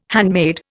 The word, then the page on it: handmade: Indian